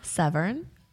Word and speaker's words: Severn: Severn. – Spell it? – Severn area. S-E-V-E-R-N.